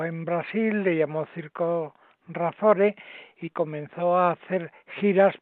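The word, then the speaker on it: Razzore: Aquí estoy y he pensado para hoy contar la historia del circo Razore es como se pronuncia con su R y tal, solo que lleva dos Z, Razore, ¿eh?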